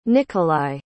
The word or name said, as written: nikolai